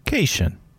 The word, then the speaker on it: Caitian: He is a Cation. To be honest, I don't think I've ever heard the word pronounced. – How's it spelled? – C-A-I-T-I-A-N. – Yeah, Cation.